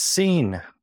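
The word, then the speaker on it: Cine: I know you have the pronunciation correct. – It's seen. – Seen, there you go.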